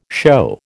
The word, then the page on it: show: American English